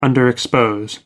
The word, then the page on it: underexpose: American English